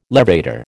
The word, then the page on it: levator: American English